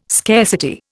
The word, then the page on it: scarcity: Indian